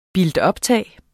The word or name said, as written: builtuptag